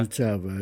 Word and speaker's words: Suceava: Suceava? È corretta la pronuncia? – Suceava, it's correct.